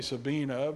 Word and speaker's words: Sabine: Just like instead of Sabine, Brother Jeff, we say Sabina because German, like Hebrew, you pronounce that ending E.